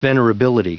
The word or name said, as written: venerability